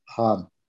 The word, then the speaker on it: Hahn: Und mein Nachname wird geschrieben Heinrich Anton Heinrich Nordpol Hahn.